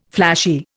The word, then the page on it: flashy: Indian